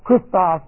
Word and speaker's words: chrestos: Now, very quickly, Christos is our word. C-H-R-E-S-T-O-S, it's a long E.